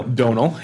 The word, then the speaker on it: Domhnall: Domhnall, yeah.